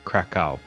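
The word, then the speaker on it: Kraków: Yeah, and they pronounce it Krakow. – Krakow.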